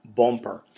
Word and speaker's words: Boomper: It's actually spelled B-O-O-M-P-E-R, pronounced Bumper.